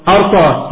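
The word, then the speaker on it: أرطاة: فإن عرطاء تنطق ولا تكتب تنطق ولا تكتب لما تكتب عرطاء تكتبها عرطاء معنانا ننطق إيه أرطاء. ليه؟ هكذا كتبت وهكذا سمعت والمسألة سمعية لا قياس لها.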